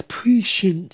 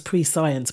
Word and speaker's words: prescience: It's prescience. And it's spelled I thought it was pre-science, but it's not. It's prescience. P-R-E-S-C-I-E-N-C-E.